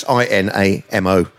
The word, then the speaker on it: Inamo: I hope I pronounced it right. It's Inamo.